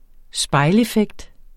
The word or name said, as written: spejleffekt